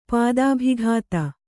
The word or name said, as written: ಪತಿಭಕ್ತೆ